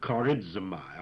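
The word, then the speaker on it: Charizomai (χαρίζομαι): Charizomai, charizomai, Wherever there's a Z in Greek, it always sounds as if it has a D before it. And we say charizomai.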